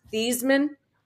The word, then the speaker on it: Theismann: But did you know his original name pronunciation was Theismann? And he changed it to Theismann so it would rhyme with Heisman.